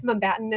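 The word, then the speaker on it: Mumbattan: And then did they say it's Mumbatan? Is that how you say it? – Yeah, it's like Mumbai and Manhattan.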